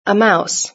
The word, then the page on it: a mouse: British English